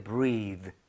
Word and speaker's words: breath: 그리고 발음도 숨 쉬다는 breathe. 그리고 숨 호흡은 breath. re. 이렇게 발음이 되죠. breath.